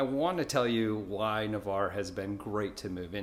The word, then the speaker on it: Navarre: If you've been looking into Navarre, it's actually pronounced Navarre, not Navarre. I've heard a lot of people make that mistake.